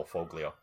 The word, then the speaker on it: Foglio: Well, you know, if you never hear it pronounced, and there's no indication that the G in Foglio would be silent. A lot of people I know call him Phil Foglio.